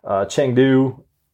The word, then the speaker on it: Chengdu: Chengdu, did I say that right? – Yep.